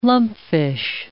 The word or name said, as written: lumpfish